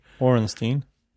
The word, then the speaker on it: Orenstein: Ben Ornstein of no, his last name isn't pronounced like that. It's pronounced in an American way, I think. – Ornstein.